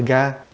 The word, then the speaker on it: gha: nó tên là ga, đọc là ga, GHA ga đó.